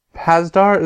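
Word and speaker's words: Pasdar: Especially, If you haven't watched Agents of S.H.I.E.L.D., Adrian Pazdar, is that how I pronounce his last name? – Yeah.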